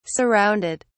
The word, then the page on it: surrounded: British English